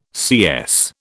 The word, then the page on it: CS: British English